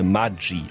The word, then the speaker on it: Maggi: Unverkennbar Maggi. Eigentlich müsste das Ganze Maggi ausgesprochen werden, italienisch. Denn Julius Maggi, Maggi, ist zwar in der Schweiz geboren, aber als Kind eines italienischen Einwanderers.